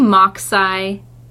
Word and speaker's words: MachSci: It's like machine science, M-A-C-H-S-C-I. – Yes. – How do you pronounce that? – I say MachSci. – Oh, MachSci. – Like Mach 5.